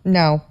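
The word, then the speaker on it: Ngo: How do you say his last name? – No. – Yeah, that's what I thought.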